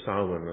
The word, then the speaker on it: Samhain: Anyway, the celebration of Halloween, or Samhain, or Samhain as it's pronounced.